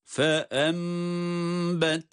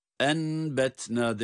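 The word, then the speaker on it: فَأَنْبَتْنَا: Yani nun mime dönüşüyor. Dikkat buyurun. – Fe embetne, enbetne değil, fe embetne diye iklab ile okunuşu bu şekilde görmüş ve tatbik etmiş oluyoruz.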